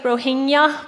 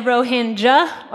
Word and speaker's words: Rohingya: Rohingya looks like Rohingya, but we're going to learn how to pronounce it today. Next slide. You can either say Rohingya or Rohingya. I'm told both are okay.